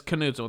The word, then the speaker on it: Knudsen: That's when people ask me how to say my name, I always say, you know, it's Knudsen with a hard K, just like Knife.